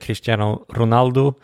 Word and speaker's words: Cristiano Ronaldo: Och då börjar jag med Cristiano Ronaldo.